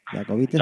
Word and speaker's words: Jacobites: Luego estaba otro grupo trascendental en su carrera, que son los Jacobites, o Jacobites, ¿cómo se pronuncia? Jacobites. – Jacobites.